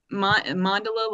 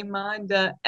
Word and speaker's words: Mandala: Mandala, like gondola. M-A-N-D-A, Mandala. Mandala.